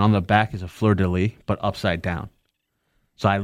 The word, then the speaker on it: fleur-de-lis: It's like fleur-de-lis. – It's a F-L-E-U-R.